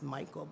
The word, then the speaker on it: Micheil: I think it's pronounced Michael, but it's spelled M-I-C-H-E-I-L.